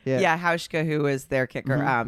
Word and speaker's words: Hauschka: It's Hauschka.